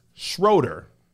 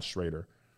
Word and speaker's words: Schroeder: This came from Dennis Schroeder. I think I said Schrader.